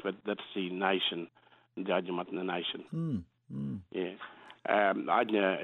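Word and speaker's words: Adnyamathanha: And that's the Adnyamutna people, is it? Did I say it right? – Adnyamutna people. Yeah.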